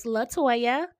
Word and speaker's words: LaToya: And then there's a space, and then there's a capital T-O-Y-A. So it's LaToya, and my last name is Bond, just like Paige.